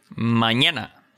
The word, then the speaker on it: mañana: Now, for those of you who are looking at the lesson notes, it's the N with a squiggle above it that makes a ñ sound, right? And you can start a syllable with it. – Mañana, España.